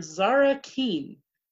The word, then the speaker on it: Zara Kean: Today, Snoops and Sleuths, I'd like to introduce you to author Zara Kean.